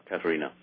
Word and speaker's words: Katharina: Are the letters between Martin and Catherine, or is that how you say her name? – Yeah, Katharina von Bora would have been her maiden name, but yeah, Katharina.